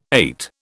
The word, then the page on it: ait: British English